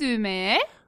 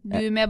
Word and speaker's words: düğme: Kırmızı, red, düğme, button. – Düğme, uh-huh. With this ü and then the silent g. So you just say düğme. You make that ü a little longer.